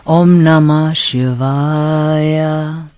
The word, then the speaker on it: Om Namah Shivaya: 然后他说我们要常诵这个什么 Om Namah Shivaya，会启动你们体内五个脉轮，将第一与第七脉轮连接，这样这么做会提升到较高的意识状态。 Oh, OK，这怎么念？Om Om Namah Shivaya。